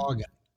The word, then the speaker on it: Haugen: It's by Thomas uh, H A U G E N. Uh, how would you pronounce that? Hagen.